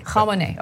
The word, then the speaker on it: Khamenei: Is it Khamenei or Khamenei? – Khamenei. – Okay, I want to make sure I pronounce it right.